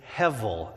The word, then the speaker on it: hevel: The word is hevel. It's spelled H-E-V-E-L. Hevel. Repeat after me.